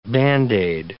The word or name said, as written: Band-Aid